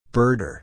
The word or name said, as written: birder